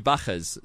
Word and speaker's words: Bachers: But the two Bachers, so B-A and Cher. Cher, if you prefer the pronunciation.